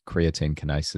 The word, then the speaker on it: creatine kinase: I had high levels of CK, which probably ultra runners have experienced as well, which is, I think it's creatine kinase is how you pronounce that.